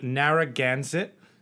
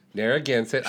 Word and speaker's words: Narragansett: We're drinking a Narragansett. – Narragansett.